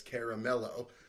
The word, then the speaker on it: Caramello: It's caramello.